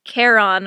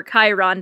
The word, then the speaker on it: Charon: Which, and it's talking about how, so the moon is called Charon or Chiron, depends on your pronunciation,